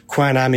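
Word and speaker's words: Quan Âm: And there are different pronunciations of her name. So she's often called Quan Yin as well. Quan Am is very much the Vietnamese spelling.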